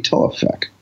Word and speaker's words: Taoufik: How do you pronounce T-A-O-U-F-I-K? Taufik. Taufik? – Yeah.